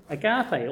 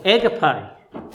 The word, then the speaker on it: agape: Agape or agape, depending on how you want to pronounce it.